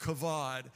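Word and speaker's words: kavod (כבוד): And when studying it, you will find the Hebrew word for glory is a word uh, pronounced kavod, and it means literally the weighted presence of God.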